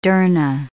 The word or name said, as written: dharna